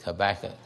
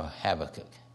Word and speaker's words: Habakkuk: Some people pronounce that Habakkuk. So you can take your pick, Habakkuk or Habakkuk.